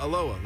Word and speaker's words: Elowah: Yeah, yeah, Elowah, like Elowah Falls.